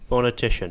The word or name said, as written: phonetician